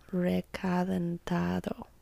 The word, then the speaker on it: Recalentado: Recalentado. – Recalentado. – Yes. – Did I say it right? – Yeah.